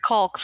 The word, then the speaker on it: caulk: You always accent, emphasize that L, guys. Well, emphasize it. It's actually caulk is how you say it. But it's so gross. – It's the word caulk. The L is silent. It's a funny game we have with our readers, because every time we say it, like, it's actually just caulk, like C-A-W-K, caulk.